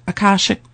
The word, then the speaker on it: Akashic: Akashic Records. Am I saying that right, Heidi? – Yeah, that's right.